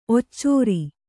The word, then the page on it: ಒಚ್ಚರ: kannada